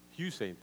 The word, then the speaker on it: Usain: Usain, thank you. I always mispronounce it.